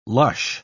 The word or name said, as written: lush